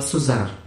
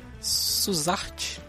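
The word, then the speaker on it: Suzart: Se pronuncia Neila Suzar. O T é mudo homem. – Suzarte? – Suzar.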